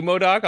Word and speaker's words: MODOK: Sorry, not Madoc. I keep Thank you, Darcy. I heard you. It slowly got through my brain. I'm like, oh, wait, I said that wrong.